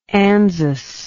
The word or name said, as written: ANZUS